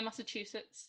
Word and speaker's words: Massachusetts: Am I saying that right? Massachusetts? – Yeah, Massachusetts, yeah.